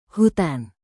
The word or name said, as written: hutan